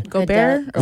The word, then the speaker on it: Gobert: Gobert? – Rudy Gobert.